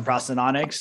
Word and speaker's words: prosthodontics: To specialize in I always forget how to pronounce this, prosthodontics. – Yep, you got it. – Specialize in prosthodontics.